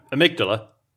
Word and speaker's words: amygdala: I did Amy G, Amy Gadala? Amy Gidala? Is that how how do you say it? Oh, amygdala. Oh, is that okay? Even yeah, if, as in the bit of the brain.